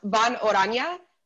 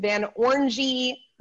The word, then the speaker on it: Von Orange: It's pronounced Von Orange, but I've heard it all. Like I said, Van Orangy, Van Orange.